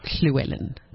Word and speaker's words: Llewellyn: Yeah, it's actually pronounced Llewellyn. It's Welsh.